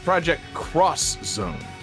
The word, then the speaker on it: Project X Zone: Project Cross Zone, Jesse. David's clearly hung up on the pronunciation. In this series, they pronounce X as cross.